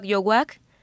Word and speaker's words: Youak: Ensamble Yowak Yowak se escribe Y-O-U-A-K repito, Y-O-U-A-K, Ensamble Yowak.